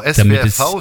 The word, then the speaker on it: SWFV: Ja, natürlich gibt es in den verschiedenen Kreisgebieten jeweilige Ansprechpartner beim Südwestdeutschen Fußballverband, also kurz gesagt SWRV. Die finden Sie zum einen auf der SWRV-Homepage. Dort sind alle Ansprechpartner auf Kreisebene. – Also ich muss es nochmal deutlich aussprechen, ja? SWFV. – Genau, SWFV, Südwestdeutscher Fußballverband.